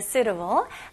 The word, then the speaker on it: suitable: Suitable. Okay, 발음 연습해 볼게요. 여기서는 suitable. 네, 여기서는 T 발음이 그렇게 생각보다 강하지는 않아요. 거의 진짜 가벼운 T. 그래서 suitable. 혀가 천장에 아주 살짝만 닿다가 바로 able. able. suitable.